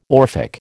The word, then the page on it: Orphic: American English